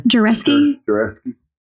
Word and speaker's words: Jarkesy: Jarkesy. – Jarkesy, they don't like each other.